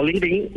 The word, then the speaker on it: LinkedIn: Bien, en cada país en realidad eh, se, toma un acento un poquito propio, entonces los americanos lo dicen como LinkedIn y aquí en Brasil, por ejemplo, lo decimos LinkedIn, pero bien, eso va a ser un poquito del acento de cada país.